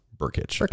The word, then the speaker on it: Brkic: It's spelled B-R-K-I-C. And it's pronounced Berkic.